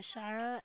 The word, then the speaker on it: Shira: It's S-H-I-R-A. Last name initial is W. – All right, thank you so kindly for that, Shira.